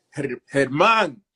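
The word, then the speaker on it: Germán: And then you get into the last name, and it really trips people out because, you know, you read it on paper in English, German, but, mm-hmm. you know, I'm Dominican. It's pronounced Herman.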